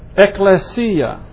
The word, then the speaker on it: ekklesia: It's the Greek word ekklesia. It's spelled E-K-K. L-E-S-I-A. ekklesia.